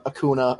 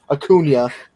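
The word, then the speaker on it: Acuña: Like, and if they do, they aren't doing anything without Acuna or Acuna. Wow, I can't believe I just mispronounced his name. I'm a fucking dunce. Acuna.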